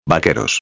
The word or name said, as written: vaqueros